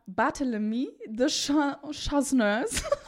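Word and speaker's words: Barthélemy de Chasseneuz: ich habe mir das aufgeschrieben, wie es ausgesprochen wird. Und zwar Barthélemy de Chasseneurs.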